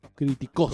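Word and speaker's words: criticos (κρυπτικός): La palabra griega es criticos, con acento en la O, criticos.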